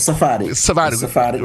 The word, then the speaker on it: Sephardic: Shepherdic, am I pronouncing it correctly, Jews, and when they were Sephardic,